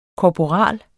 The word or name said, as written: korporal